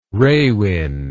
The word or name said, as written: rawin